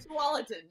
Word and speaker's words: Tualatin: Tualatin.